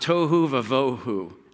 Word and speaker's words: tohu vavohu: It's this word, it's pronounced tohu vavohu. Sounds like tofu, that's not tofu.